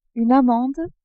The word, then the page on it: amande: French